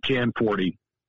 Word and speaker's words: Gianforte: So the Americanization of it is Gianforte, which is what the easiest thing, Gianforte. – Gianforte. – The original Italian is Gianforte, which is too hard for anybody.